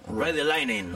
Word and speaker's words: Ride the Lightning: Ride the Lightning.